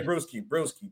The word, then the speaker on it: Bruschi: Teddy Bruschi, I always say it wrong. – Teddy Bruschi.